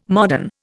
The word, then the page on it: modern: Indian